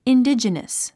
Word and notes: indigenous: indídʒənəs; インディジネス

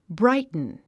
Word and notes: brighten: bráitn; ブライトゥン

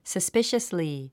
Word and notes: suspiciously: səspíʃəsli; サスピシャスリィ

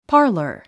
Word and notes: parlor: pάːrlər; パーラァ

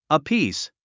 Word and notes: apiece: əpíːs; アピース

